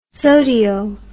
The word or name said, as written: sodio